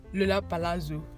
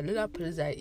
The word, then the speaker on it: Lollapalooza: Do you know I was calling Lollapalooza Lollapalooza Lollapalazzo? I butchered Lollapalooza. Lollapalooza is a big US festival.